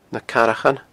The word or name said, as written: nacarrachan